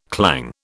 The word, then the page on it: clang: British English